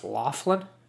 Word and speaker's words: Laughlin: Like when you look at it, if you're not a native English speaker and you look at it, you'd probably think it's pronounced Laughlin. But it's not. It's pronounced Laughlin, you know?